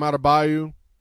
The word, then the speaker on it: Adebayo: Last night, this being Wednesday, so it was Tuesday night, we had the big block from, was it Abaju? How do you say his name? The dude from the Heat. – Bam Adebayo.